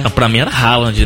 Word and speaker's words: Haaland: O Haaland.